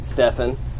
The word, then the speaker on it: Stefan: Stefan. With an F.